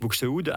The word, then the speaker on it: Buxtehude: Quelques mots sur le choix de cet Alléluia de Buxtehude. Oui. Est-ce que je prononce bien ? – Je pense. En tout cas, je l'ai compris. Oui, c'est l'Alléluia de Dietrich Buxtehude.